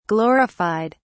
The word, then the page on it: glorified: British English